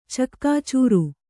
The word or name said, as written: ಚಕ್ಕಾಚೂರು